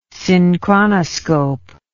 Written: synchronoscope

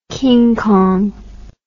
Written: King Kong

